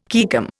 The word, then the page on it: caecum: Indian